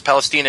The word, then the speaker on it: Palestina: Palestina is how you say Philistina in Roman because they don't have much of an F sound.